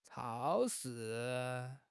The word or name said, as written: chao1 shi4